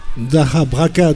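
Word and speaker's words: DakhaBrakha: Dacabraca, donc comment on le prononce en ukrainien, ça veut dire donner et prendre, c'est un échange en fait, Dacabraca.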